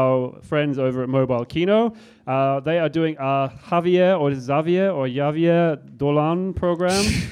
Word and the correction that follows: Xavier: How do you pronounce that, somebody? Correct me. – Xavier Dolan. – Xavier? Okay, there we go.